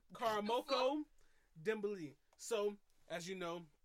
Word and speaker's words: Karamoko: 15-year-old soccer wonder boy, Karamoku Dembele. – Why in the hell did you? Why'd you say "koo"? It's Karamoko. – Karamoko Dembele.